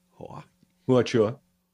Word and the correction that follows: Huachuca: No, Huachuca. Shit, I don't know what you said. – Huachuca.